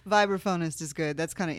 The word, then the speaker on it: vibraphonist: I did not know that you're a professional vibraphonist. Saying that right? Vibraphist? – Vibraphonist, that's right.